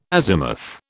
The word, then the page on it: azimuth: British English